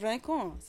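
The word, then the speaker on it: Rincón: Roll your R. – Rincon. She said Rincon. So I said, okay, it's called Rincon.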